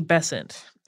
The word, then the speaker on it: Besant: In 1888, the Bryant and May match factories came to the attention of Annie Besant. Most American pronunciations of this seem to rhyme with Crescent, so we're going with that.